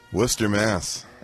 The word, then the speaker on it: Worcester: Worcester, Mass. – They pronounce it Worcester? – They do in Massachusetts.